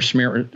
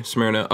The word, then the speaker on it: Smyrna: It's in I don't know how you pronounce it, Smyrna or whatever. – Smyrna, Georgia. Okay, Smyrna.